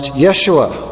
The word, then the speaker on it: Yeshua: Now, Joshua is a Hebrew name adapted to English, but in Hebrew, it is pronounced Yeshua, which means the Lord is salvation.